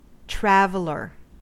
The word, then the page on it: traveler: American English